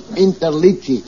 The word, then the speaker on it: Interliggi: My name is Joseph Interligi. I-N-T-E-R-L-I-G-G-I.